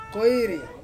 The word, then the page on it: koiri: Ansus